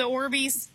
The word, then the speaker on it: Orbeez: Oh, the Orbeez? – Yeah.